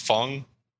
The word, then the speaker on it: Fang: Yeah, I call it Fang. It's pronounced Fung. Like, if you like shoot something out of a tube, it's like Fung, like that. But that's too hard, so I just say Fang.